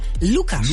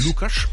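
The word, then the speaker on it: Łukasz: Vielleicht auch ein Grund, warum Lukas Podolski, wobei richtig ausgesprochen heißt er Lukas. – Lukas.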